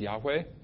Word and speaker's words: Yahweh: And it's, a lot of scholars today believe that was probably pronounced Yahweh.